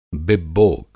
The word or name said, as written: बिब्बोक